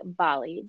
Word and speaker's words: Bally: So without further ado, our special guest today, ladies and gentlemen, is Natalie Bally.